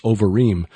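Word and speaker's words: Obarim: which in Hebrew the word there is Ovarim. But it references place names. – Yeah, it's spelled O-B, I think. – Right. – But it's pronounced with like a V. – Yeah. – So if you're trying to look it up, it's O-B-A-R-I-M.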